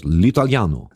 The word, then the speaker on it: L'Italiano: Aber toll, das Ding heißt, ja, wie spricht man es aus? L'Italiano. – Si, si.